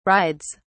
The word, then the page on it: rides: British English